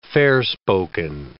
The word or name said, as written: fair-spoken